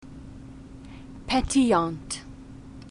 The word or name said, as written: petillant